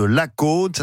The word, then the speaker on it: Lacaune: Pour la balade du savoir-faire, direction l'Occitanie, à la découverte du jambon de la Côte. Ça s'écrit L-A-C-A-U-N-E.